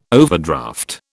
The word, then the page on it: overdraft: British English